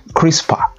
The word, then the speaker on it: CRISPR: It is pronounced CRISPR.